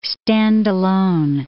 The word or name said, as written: stand-alone